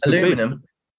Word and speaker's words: aluminium: I just can't believe how difficult this aluminium. – Could be. – You said it right.